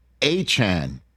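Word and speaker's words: Achane: And then you see Tyreek Hill and Devon Achan is apparently how he wants his name to be pronounced.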